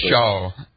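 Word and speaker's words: D'Brickashaw: I hope I'm pronouncing his first name correctly. – DeBrickashaw is how you pronounce it.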